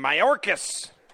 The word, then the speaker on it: Mayorkas: Mayorkas.